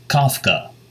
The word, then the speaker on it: Kafka: Pronounced Kafka, like cough drops or coffee.